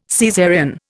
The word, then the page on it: Caesarean: Indian